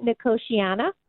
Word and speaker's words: Nicotiana: I planted, I believe it's pronounced Nicotania. And it has these beautiful white trumpet flowers on it. And I'm curious how big this plant gets because it's just becoming massive. – Yeah. So in my neck of the woods, we pronounce it Nicotiana.